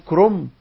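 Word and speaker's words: كرنب: لما تيجي تكتبها كاف راء نون داء، لكن لما تنطقها تنطقها ازاي؟ كلنا بنقول كرومب، ولا في حد بيقول كرومب؟ لا، كله بيقول ايه؟ كرومب. فاحنا بننطق كلمات كتيرة بأحكام التجويد، فكلمة كرومب النون دي بعمل لها بقلب نون إلى ايه؟ إلى ميم، كرومب، كرومب.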